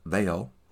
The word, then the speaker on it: Mhaol: They're called Vale, and uh, that's spelled M H A O L. Uh, yeah, because I believe it's what Gaelic. Is that right? Uh, yeah, but it's spelled M H A O L, uh, pronounced Vale, or something close to that.